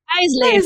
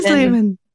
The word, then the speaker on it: Eisleben: Eisleben. Eisleben. You're right. Everyone can call out my terrible German pronunciation today.